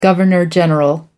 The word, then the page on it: governor general: American English